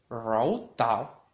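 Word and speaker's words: row tau (柔道): Judo en chino se dice row tau, row tau, row tau, con un segundo tono al lado de row y un cuarto tono al lado de tau, row tau, row tau.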